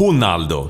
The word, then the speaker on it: Ronaldo: Ja, men alltså, vi har en kollega som fick nys på att den gamle Ronaldo på portugisiska ska uttalas Ronaldo.